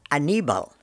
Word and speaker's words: Aníbal: My name is Aníbal, which is kind of the English version of Hannibal.